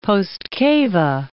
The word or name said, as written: postcava